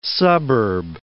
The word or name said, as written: suburb